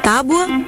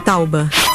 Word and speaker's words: tábua: Então, a forma correta é tábua. Lembre-se de que o U aparece na segunda parte, não na primeira, não é tauba, é tábua. Beleza? De novo, tábua.